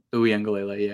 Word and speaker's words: Uiagalelei: Uiagalelei, yeah.